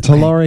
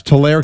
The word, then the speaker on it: Tulare: And I want to thank Anonymous in, I hope I say this right, is it Talor or Tulare, California. – Oh, there it is. – Tulare, okay. California. You see that? – I do. – Tulare. It's Tulare, California.